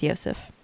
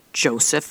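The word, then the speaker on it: Josef: And actually, I just looked it up. It is Yosef. – Is it Yosef? Okay. Mm-hmm. When I put it in the pronunciation thing, it said Joseph Fritzl. But that's probably the American pronunciation. – Yeah, so I looked I'll stick with Yosef. – I looked up an Austrian pronunciation. It's Yosef Fritzl.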